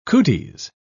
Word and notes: cooties: British English

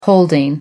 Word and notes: holding: American English

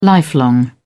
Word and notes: lifelong: British English

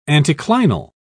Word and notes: anticlinal: British English

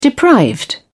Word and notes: deprived: British English

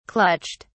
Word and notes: clutched: British English

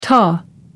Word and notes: ta: American English

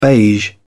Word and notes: beige: British English

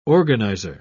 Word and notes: organiser: British English

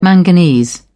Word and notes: manganese: British English

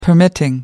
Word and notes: permitting: British English

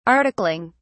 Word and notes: articling: British English

